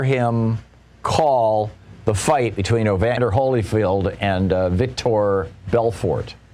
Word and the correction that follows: Vitor Belfort: I may be mispronouncing that. I don't follow boxing. But say that again. – Vitor Belfort. – Vitor Belfort. Thank you, Nate.